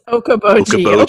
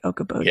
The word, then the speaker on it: Okoboji: It's Okaboji.